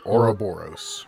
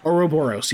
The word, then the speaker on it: Ouroboros: So the clearest association that might come to mind here is the image of the Ouroboros. How do you say that? – Ouroboros. – Ouroboros, yes, that's it.